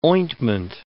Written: ointment

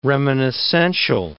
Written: reminiscential

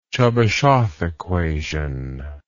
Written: Chebyshev equation

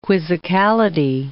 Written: quizzicality

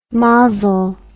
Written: mazel

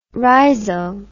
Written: rhizo

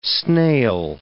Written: snail

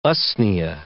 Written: usnea

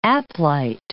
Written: aplite

